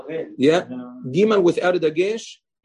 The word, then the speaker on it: gimel: Temanim pronounce it differently, but according to the Sfaradim, our minhag, as brought down in Rav Pa'alim as well, gimel is with a dagesh is pronounced gimel. G. – Like a G. – Huh? Like a hard G.